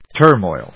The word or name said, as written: turmoil